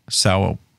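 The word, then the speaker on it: São: I can never pronounce S-A-O. Sao Paulo Forum.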